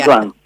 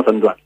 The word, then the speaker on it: Antoine: No sé si estoy pronunciando bien el francés. – Antoine. Bueno, yo tampoco tengo un francés, así que no puedo calificar como buen. Pero bueno, sería, nosotros le decimos Antoine.